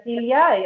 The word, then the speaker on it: Silja: Mi nombre se pronuncia Silvia, y hay que pensar en una silla más o menos.